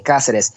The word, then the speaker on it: Cáceres: Cáceres. Emphasis on the A. Cáceres.